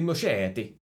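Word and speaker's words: Muschietti: But the director of that movie and its prequel, and the Moschetti. – Yep, that's how you say it.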